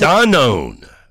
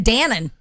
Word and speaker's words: Danone: That's because Danone is recalling its all of Danone. That's the way it's pronounced. – I was always saying Dannon.